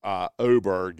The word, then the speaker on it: Åberg: I think it's actually Åberg is the proper pronunciation, but no one's ever accused me of having perfect diction, but he's his set of skills.